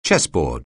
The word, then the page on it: chessboard: English